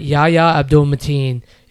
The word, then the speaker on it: Yahya Abdul-Mateen: But Yahya Abdul-Mateen is absolutely fantastic when he's in the lead role.